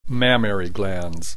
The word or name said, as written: mammary glands